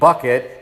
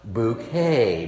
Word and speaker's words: Bucket: And she doesn't allow anyone to pronounce her name Bucket. It's what? Bouquet.